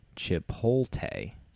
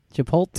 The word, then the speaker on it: Chipotle: I do want to point out that in the pre-show, Jonesy pronounced the restaurant chain as Chipotle. – Right. Is that not what it is? Chipotle. – It's actually Chipotle. – That is the correct pronunciation.